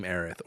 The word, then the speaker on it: Aerith: They can name, name Barret, and they could probably name Aerith or Eris, whichever way you say it. We like both pronunciations here at this place.